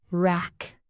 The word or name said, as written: wrack